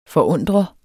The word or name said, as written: forundre